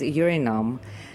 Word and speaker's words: Eurynome: And then there is Urinom, which is spelled Eurynome.